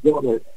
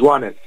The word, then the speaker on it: Joanes: How do you pronounce your first name? – Well, the French pronunciation is Joanes. – Joanes. – English, they call me Jonas. They call me Jonas. – You know, I'll tell you. – It's easier, but it's Joanes. – Joanes.